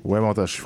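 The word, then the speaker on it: Wemotaci: Auparavant, était directeur du développement économique ainsi que directeur général adjoint du Conseil des Atikamekw de la communauté Wamdashi. Si j'ai bien prononcé. – Wamondashi, François.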